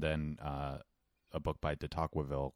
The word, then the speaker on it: Tocqueville: But it's T-O-C-Q-U-E. That's what throws me off. It sounds like it would be Tocqueville. No qua.